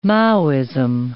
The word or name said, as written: Maoism